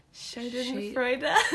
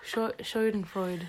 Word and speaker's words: Schadenfreude: And I think the new, like, I think it's Schadenfreude, is how you say it. – Schadenfreude. – I did German for 5 years. Schadenfreude.